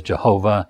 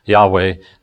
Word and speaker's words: Yahweh: We pronounce it in the old days Jehovah, and lately Yahweh.